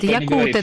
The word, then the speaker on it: якуты: Большой толковый словарь русского языка в слове «якут» ставит ударение на «у». Я быстренько это проверила. – Нет, так про множественное число мы говорим, про якуты. – Нет, якуты также, да, там также на «у», ну, на всякие пожарные.